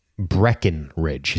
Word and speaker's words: Breckenridge: Yeah, Alexandra Breckenridge is how you say it.